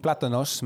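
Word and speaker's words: plátanos: I was making platanos mainly, which is the correct way to say this fried banana thing that you two are arguing platanos.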